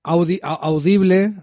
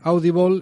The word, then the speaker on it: Audible: Yo lo estoy viendo en audiolibro, porque está Audible, que lo dije mal la otra vez. Audible, Audible es un sistema de videolibros,